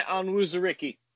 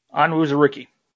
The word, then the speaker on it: Onwuzurike: How do you pronounce this guy's name? – Levi Onwuzurike. – Onwuzurike. Okay.